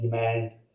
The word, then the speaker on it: Hemant: No, this would hopefully prevent my American friends or English friends from mispronouncing my name. So they would frequently call me Hemant.